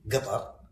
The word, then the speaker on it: Qatar: So most people in Qatar would say Qatar. Qatar. And most Arabs would Qatar. Qatar. – So the proper way of pronouncing it is Qatar.